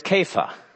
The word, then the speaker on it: Kepha: In Aramaic, the word is kepha. We often pronounce it in English when it's found in the New Testament, where it is several times, Cephas, but it's actually Cephas or Kepha, and that is the Aramaic word for rock.